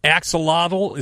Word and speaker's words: axolotl: Even so, the axolotl, is that how it's pronounced? Axolotl? – Yeah. – It's A-X-O-L-O-T-L.